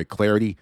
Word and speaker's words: Clardy: And of course, you can also follow me on Twitter as well at Troy Clardy.